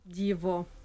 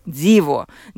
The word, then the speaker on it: Диво: А вот бренд как звучит? – Диво. – Диво.